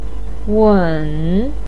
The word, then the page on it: weng3: pinyin